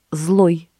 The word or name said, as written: злой